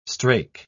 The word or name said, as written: strake